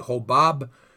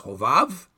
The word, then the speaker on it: Hovav: Now, your Bible probably will say Hobab or Hobav. Actually, in the Hebrew, it's pronounced Hovav.